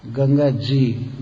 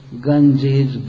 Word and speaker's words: Ganga Ji: Ganga Ji, Ganga Ji, but they could not pronounce properly, so they Ganjis, Ganjis, they were saying like this. But actually the real word is Ganga Ji.